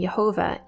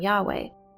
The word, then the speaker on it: Yahweh: Therefore, the pronunciation Yehovah is a faulty pronunciation of the name Yahweh.